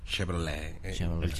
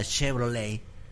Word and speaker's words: Chevrolet: Que viene de un apellido francés. – De un apellido. – Apellido francés. ¿Cómo se dice? – Chevrolet. – O sea, la Blazer Chevrolet. – No se Chevrolet. – Dice en la última letra. – Chevrolet. – El Chevrolete.